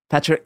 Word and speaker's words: Patriota: Frente Patriota. How would you say that? How would you say that? – Patriota.